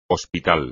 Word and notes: hospital: espagnol

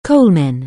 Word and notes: coalman: anglais

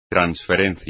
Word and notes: transferencia: espagnol

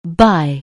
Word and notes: by: anglais

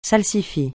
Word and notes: salsifis: francais

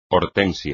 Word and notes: hortensia: espagnol

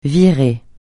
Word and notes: virée: francais

